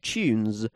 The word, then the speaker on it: Tunez: Now, at the Life in Hex Lands, we use one called Tunes, which is spelled T-U-N-E-Z.